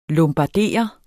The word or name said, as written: lombardere